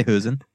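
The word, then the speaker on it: Hoesen: So I think in the Dutch pronunciation, it's like somewhere in between. – It's like a Hosen? – Yeah. – Okay. I'll say Hosen.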